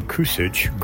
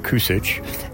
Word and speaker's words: Kusic: My name, it's pronounced the last name Kusic, Greg Kusic, and I'm a recently retired professor of English or British literature from the University of Notre Dame in America near Chicago.